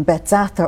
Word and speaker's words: Bethzatha: But the meaning of the name Bethzatha or Bethesda, depending on how they pronounce it, is house of mercy.